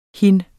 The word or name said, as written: hin